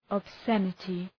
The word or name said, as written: obscenity